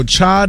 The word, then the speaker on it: chai: National Cha Day. – Okay. – Did I say that right? – Yes. Like chai lattes.